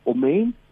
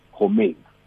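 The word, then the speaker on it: Ohmein: Okay, I will spell it for you. – Yes. – So, omein. – Oh, omein, okay. – Yes, it's O-H-M-E-I-N. Homein.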